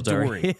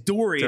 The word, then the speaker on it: Doerrie: And it's Rachel Dory. – It's Dory. And it's spelled like Dory.